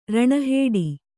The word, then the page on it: ರಣಹೊಡೆ: raṇa hoḍe